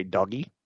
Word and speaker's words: Dogecoin: You can make a GJ sound or you can just say doggy.